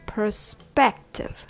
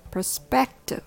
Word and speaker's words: perspective: Three syllables with the stress on the second syllable, spect, perspective. The rhythm goes ta ta ta, perspective.